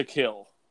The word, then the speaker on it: Kiln: There's a city in the coast of Mississippi, not far out of New Orleans, called, it's spelled, shut up, it's spelled K-I-L-N. They pronounce it there, to kill. It's not kiln. – K-I-L-N, they say to kill? – I promise you.